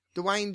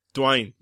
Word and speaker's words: Dwayne: Dwayne. Like Wayne, but with a D at the start. – Yeah, Dwayne. Dwayne Johnson. – Dwayne. Where's Dwayne? – Shut up! – One syllable. Dwayne. – Dwayne.